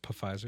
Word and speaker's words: Pfizer: I said Pfizer with no P. – Can't wait. – Just like a pterodactyl. You know why you can't hear a pterodactyl use the bathroom? – It's pronounced Pfizer. – Because the P is silent.